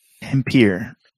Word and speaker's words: Tampere: Tampere or something like that it's pronounced? It's like a town an hour or two north of Helsinki. – Tampere.